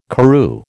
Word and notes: Karroo: American English

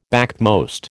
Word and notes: backmost: American English

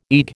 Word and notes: EEG: American English

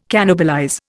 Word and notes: cannibalize: Indian

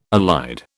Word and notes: allied: British English

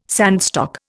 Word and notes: sandstock: Indian

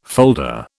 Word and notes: folder: British English

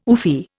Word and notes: oofy: Indian

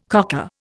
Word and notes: cocker: Indian

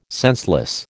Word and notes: senseless: American English

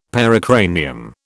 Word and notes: pericranium: British English

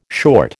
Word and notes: short: American English

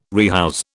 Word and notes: rehouse: British English

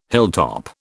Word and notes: hilltop: British English

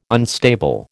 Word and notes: unstable: American English

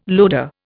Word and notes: loader: Indian

